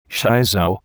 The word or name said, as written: shiso